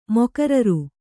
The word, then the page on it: ಮೊಕರರು: mokararu